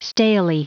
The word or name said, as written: stalely